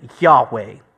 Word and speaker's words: Yahweh: And so God here reveals himself with a divine personal name, which we would likely, we would pronounce it Yahweh.